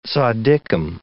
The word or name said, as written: zaddikim